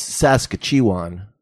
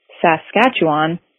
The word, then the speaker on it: Saskatchewan: It's Saskatchewan.